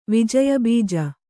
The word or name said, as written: ವಿಜಯಬೀಜ